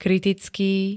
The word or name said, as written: kritický